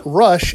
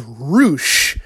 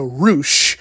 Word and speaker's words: Rush: And his name is actually not Rush, it's pronounced Roosh. So think of like a kangaroo and then add SH, so Roosh.